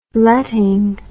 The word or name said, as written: letting